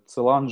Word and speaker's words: Целан: Более того, а, Целан жил в Париже, ударение на последний слог, значит.